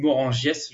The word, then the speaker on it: Morangiès: on dit de Morangias, je crois. C'est Morangias en réalité. – Ouais, c'est ça, c'est Morangiès. Bah, ça, bah, ça s'écrit Morangiès, c'est ça, hein, ouais. – Ouais, c'est dans le pacte des loups qui disent euh, Morangias. – Ouais, c'est ça, mais c'est Morangias en vrai.